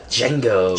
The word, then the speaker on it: Django: Django. – Django. – The D is silent.